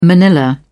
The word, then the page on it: manilla: British English